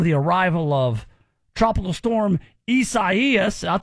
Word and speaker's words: Isaias: Come on, Isaias. That's how you say it.